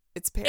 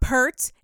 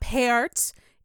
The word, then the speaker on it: Peart: It's not Pert. It's not Peart. It's Peart. That's how you fucking pronounce it. – It's like pear with a T. Peart. – Literally, it's the word pear. With a T on the end.